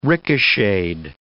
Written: ricocheted

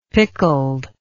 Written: pickled

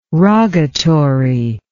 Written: rogatory

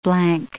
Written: blank

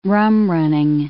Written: rum-running

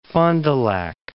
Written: Fond du Lac

